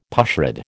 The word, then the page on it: pushrod: American English